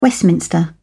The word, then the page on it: Westminster: British English